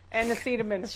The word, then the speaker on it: acetaminophen: And sinfin. And sinmin. – Acetaminophen. – What is the word? That's a hard one. How do you say it? Acetaminophen, sir. Acetaminophen.